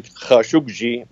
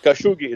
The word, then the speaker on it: Khashoggi: And I'm pronouncing you Jamal Khashoggi. Is that correct? – To my American friends, I say Khashoggi. It is actually Khashoggi, but that would be very hard to say. Khashoggi is good.